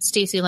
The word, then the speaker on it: Stacey: Um, there's still a lot more I run tech that is out there, but on Twitter, you can find me at Stacy Lindis.